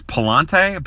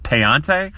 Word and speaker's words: Pallante: Prospect number 23 uh, uh, was a fourth rounder, Andre Pallante, spelled P A L L A N T E, which I always thought was Palante, but I heard uh, Randy Flores pronounce it Payante, so we're going to go Payante.